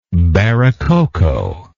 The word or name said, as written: barococo